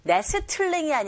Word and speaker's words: nestling: nestling이 아니라 nestling. Nestling. 트 발음 역시 안 들리죠.